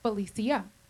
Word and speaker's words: Felicia: My name is pronounced Felicia. It's Latin, and it means happy.